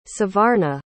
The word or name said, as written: savarna